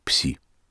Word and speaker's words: Пси: Где-то в течение лета выяснилось, что будущий альбом будет называться «Символом», а название этого символа произносится как «Пси».